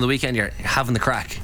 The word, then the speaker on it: craic: You usually have the crack. – The crack. – Not the. – No. You're not get. Yeah. – We spell it C-R-A-I-C.